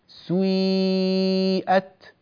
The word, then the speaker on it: سيئت: هكذا ننطق هذه الكلمة فنقول سويئت سوي سوي، ماذا فعلنا؟ نطقنا بسين مكسورة إلا أننا ضممنا الشفتين في أول هذه السين، نطق دقيق جدا سوي سوي.